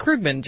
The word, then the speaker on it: Krugman: You know it's pronounced Krugman, Jim.